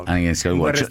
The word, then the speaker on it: Anakin Skywalker: Anakin Skywalker.